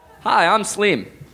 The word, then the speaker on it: Sleem: S-L-E-E-M. Sleem. – Just what I thought. Just what I hoped. Hi, I'm Sleem.